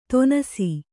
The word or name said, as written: ತೊನಸಿ